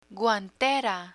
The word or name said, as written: guantera